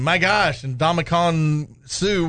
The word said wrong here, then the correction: Ndamukong Suh: And Ndamukong Suh?